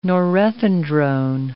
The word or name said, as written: norethindrone